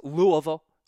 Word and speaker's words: Louisville: If you've ever been in the area, it's Louisville.